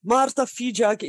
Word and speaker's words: Marta Fijak: It's not an easy one. I heard many versions. Marta Fijak is the most common one. So that's perfectly fine.